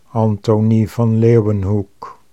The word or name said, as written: Anthonie van Leeuwenhoek